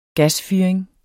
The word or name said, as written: gasfyring